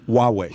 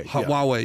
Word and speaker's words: Huawei: About a year back, and he basically said, "If you let highway, how do you pronounce that? – Oh yeah, highway. – Huawei. – Huawei. Yeah, Huawei.